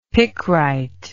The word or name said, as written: picrite